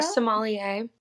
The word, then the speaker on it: sommelier: I know that you're, and I'm going to mispronounce this word because I do every time, sommelier. At your family's restaurant? – Oh, a sommelier. – Sommelier. Wow. Okay, thank you.